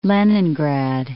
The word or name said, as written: Leningrad